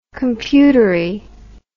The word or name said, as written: computery